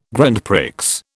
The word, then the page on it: Grand Prix: British English